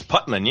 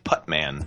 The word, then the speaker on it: Putman: It's Putman, yeah, like a man. M-A-N, Putman. – Putman, like a man. You putt like a man. – It's not a Putnam. For some reason, that's the popular pronunciation. – Well, I have no idea why, because man sounds much more natural than Nam.